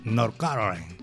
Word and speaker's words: North Carolina: Así, así se dice, North Carolina.